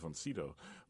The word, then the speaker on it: Sydow: And it is, in fact, Von Sydow. But he said that it was confusing even for Swedes because it is, in fact, a German name, right.